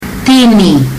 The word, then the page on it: tini: fiji